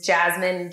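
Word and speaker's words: Jazmin: My handle is Jasmine J. Hicks. That's J-A-Z-M-I-N. J-H-I-C-K-S.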